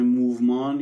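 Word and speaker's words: mouvement: یعنی اساسا اسم موومان یا موومنت به انگلیسی، موومان در واقع تلفظ فرانسوی شد، برمیگرده به تمپو.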